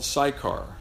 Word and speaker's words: Sychar: She's in a town called Sychar. Did I say that right, Don? Mm-hmm.